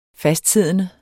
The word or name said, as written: fastsiddende